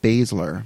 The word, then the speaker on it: Baszler: Shayna Baszler. – Baszler. She was the favorite to win, but she did not sign a contract with the WWE. However, her opponent did. Ergo, she went over Baszler.